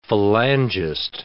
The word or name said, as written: Falangist